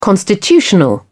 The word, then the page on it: constitutional: British English